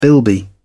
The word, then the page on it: bilby: British English